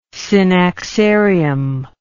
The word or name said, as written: synaxarium